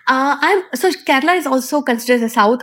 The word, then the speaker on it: Kerala: Kerala.